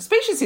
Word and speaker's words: specious: Specious is actually only two syllables.